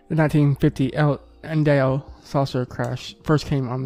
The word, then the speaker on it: Indio: To the UFO researcher, this case is known as the crash at El Indigo. No. – Indio.